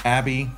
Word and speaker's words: ABBYY: But in today's world of technology, you have many apps that can accomplish the collection and storage of this information, such as ABBY, which is spelled, I'm gonna spell it for you, ABBYY, right? So it's a business card reader, and you also have CamCard.